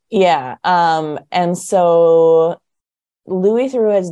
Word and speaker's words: Theroux: So are you familiar with the BBC documentarian Louis Theroux? – No.